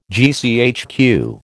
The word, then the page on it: GCHQ: American English